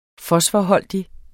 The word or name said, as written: fosforholdig